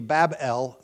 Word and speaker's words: Babel: If you hyphenate it after the B, Bab, after the second B, Bab-El means the gate of God or the gateway to God. But if you take out the hyphen and just pronounce it Babel, it means confusion.